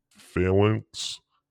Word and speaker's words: phalanx: We still need a division representative for the Hoplite and Phalanx. How do you say that? – Phalanx.